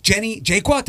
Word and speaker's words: Jaqua: Jenny Jaqua, did I did I say it right? Yes. Awesome. – Yes. – You did great.